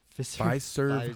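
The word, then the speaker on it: Fiserv: Fis, Fizzer? – Pfizer Pfizer. Forum. Pfizer Forum.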